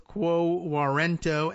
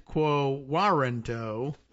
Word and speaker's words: quo warranto: By the way, two pronunciations available for this, it says here, pronounced both quo warranto and quo warranto, Latin for by what authority, as in by what authority does this person hold this office?